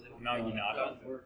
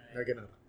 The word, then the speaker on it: Naginata: The Nagita? – Naginata. – Yeah, sure. Naginata. If you want to actually pronounce things correctly.